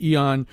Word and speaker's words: Aeon: And thanks very much to you, our guest, Todd Pittock, who wrote about this for what we've now decided is pronounced Eon. But it's spelled A-E-O-N.